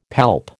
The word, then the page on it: palp: American English